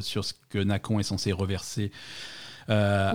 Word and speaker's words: Nacon: Euh, on, va, on va les appeler Nacon, hein, on va prononcer ça Nacon puisque c'est un éditeur français.